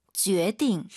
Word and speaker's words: 决定: 많은 초급자분들이 선생님 이것도 주에 띵, 주에 띵 쌍디귿을 많이 하는데 사실 들어볼게요. 선생님 발음. – 决定. – 힘 빼고 발음하는 게 포인트예요.